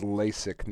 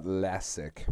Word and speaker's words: LASIK: That is LASIK, not Lassick.